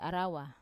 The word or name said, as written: arawa